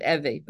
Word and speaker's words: Evie: So, I spell it the same way, Evie. We pronounce it Evie, but it's funny.